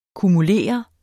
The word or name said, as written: kumulere